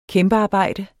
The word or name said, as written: kæmpearbejde